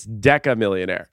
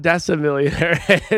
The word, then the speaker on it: decamillionaire: It's decamillionaire. So it is not decimillionaire. It's decamillionaire.